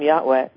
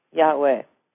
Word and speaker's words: Yahweh: Well, people say Yahweh, but actually, to show respect, there would be a glottal stop between the two syllables. So I try to say it that way, just like if I say, if I happen to mention Muhammad, I say, may he be blessed.